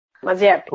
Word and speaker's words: Mazepin: Que foi uh, a estreia de três novatos no grid, que foram Nikita Mazepin, tá certo? Se vai lá pronunciei, Mazepin. – Mazepin.